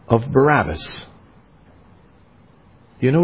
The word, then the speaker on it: Barabbas: We might, if we were Hebrews, we would more accurately pronounce his name Bar-Abbas.